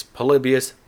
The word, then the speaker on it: Polybius: I just want to put that out there, as it is Polybius, not Polybius. Even though it's fictional, so who knows.